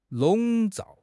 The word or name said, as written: 笼罩